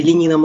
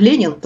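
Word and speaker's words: Lenín: Ну, прежде всего, хочу сказать, что я была в Эквадоре на выборах на предыдущих, по итогам которого как раз избрали Ленина Морено, он как Ленин, только с ударением на другой слог, Ленин, и который тогда шел на выборы как левый кандидат, в противовес тому же самому Лассе, который победил сейчас.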